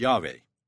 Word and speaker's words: Yahweh: In Hebrew, the true name of God is YHWH, pronounced Yahweh, and the true name of Jesus is YHSWH, pronounced Yahshua.